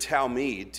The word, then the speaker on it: Talmid: The word Talmid, T-A-L-M-I-D, is pronounced Talmid, translated to disciple or student.